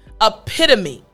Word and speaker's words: epitome: Again, the word is epitome. Epitome. So I want to help you with the p sound.